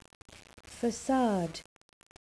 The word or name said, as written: facade